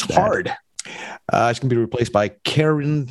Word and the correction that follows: Karine: Karine. There's an E on the end.